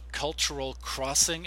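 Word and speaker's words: Cultural Xing: So, again, for those of you who want to find out, Cultural Crossing is the name of the company that Jeri started. It's uh, spelled with an X, so it's Cultural X I N G, Cultural Crossing.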